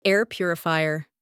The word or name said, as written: air purifier